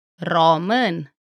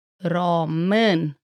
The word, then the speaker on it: Român: Pronouncing this letter just takes a little practice, because there is no equivalent English sound. In Romanian, it sounds like this Român. Român.